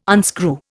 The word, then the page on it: unscrew: Indian